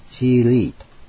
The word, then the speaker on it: 激励: 激励という漢字、そのまま現代の中国語でもあるんですね。現代ではチーリーと発音するわけですね。